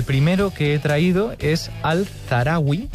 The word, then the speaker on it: Al-Zahrawi: Ya me dirá Miquel si lo he pronunciado bien o mal. – Al-Zahrawi. – Sí. Perfecto, pues pero lo he pronunciado bien. Mal. Es una buena manera de no decírmelo abiertamente. – Sí, no, bien, bien.